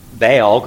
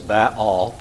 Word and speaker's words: Baal: So Baal is going to get a lot of attention. It's actually two syllables, Ba'al, but you run them together fast and they just sound like Baal.